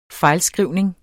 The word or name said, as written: fejlskrivning